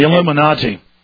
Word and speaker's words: Illuminati: And I was watching his show on TBN, the Trinity Broadcasting Network, and he was talking about the Illuminati, I think is the way you pronounce it. – The Illuminati.